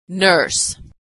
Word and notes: nurse: American English

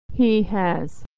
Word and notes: he has: American English

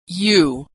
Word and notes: you: American English

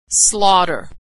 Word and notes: slaughter: American English

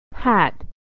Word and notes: hat: American English

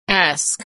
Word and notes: ask: ae

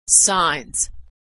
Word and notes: signs: American English

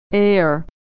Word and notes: heir: American English

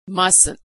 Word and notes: mustn't: American English

